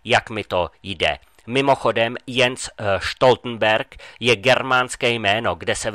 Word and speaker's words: Jens Stoltenberg: A ten Stoltenberg, to je čistá tragédia. To nie je žiadny cukrík, je to Jens Stoltenberg.